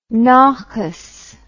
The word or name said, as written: naches